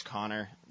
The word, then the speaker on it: Connor: My name's Connor.